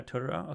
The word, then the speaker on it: Totoro: Is that how you say it? Totoro? – Yeah, I say Totoro. – Yeah, Totoro. Okay, okay.